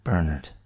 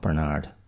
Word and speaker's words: Bernard: And that's Bernard, not Bernard, as I mispronounced it in the podcast.